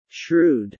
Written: shrewd

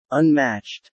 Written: unmatched

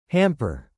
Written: hamper

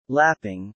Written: lapping

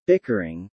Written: bickering

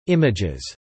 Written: images

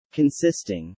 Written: consisting

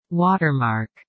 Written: watermark

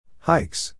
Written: hikes

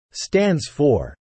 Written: stands for

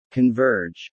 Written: converge

